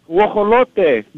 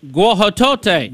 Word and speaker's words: guajolote: Guajolote. – Guajolote.